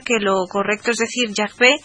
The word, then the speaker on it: Yahvé: Por tanto, esto da también preeminencia a la pronunciación Yahvé, de nuevo como la correcta.